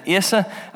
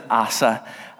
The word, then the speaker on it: Asa: Some people pronounce it Asa, I pronounce it Asa.